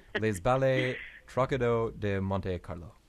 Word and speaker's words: Les Ballets Trocadero de Monte Carlo: Now, tell me about Les Ballets de Trocadero, and please correct my pronunciation, but Les Ballets Trocadero de Monte Carlo. – Well, you did very well. There is no reason to correct.